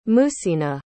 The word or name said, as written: muhsina